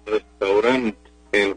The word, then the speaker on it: restaurante: Restaurar es válida porque es la pronunciación figurada de la palabra restaurant en francés, la terminada en T. Ahí se pronuncia más o menos restaurant, y por lo tanto la adaptación a la fonética española pues es restaurant, es muy común. Pero la forma más recomendada sin duda es restaurante, restaurante porque es la acción de restaurar.